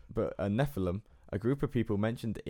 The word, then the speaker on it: Nephilim: Nephilim. – Nephilim.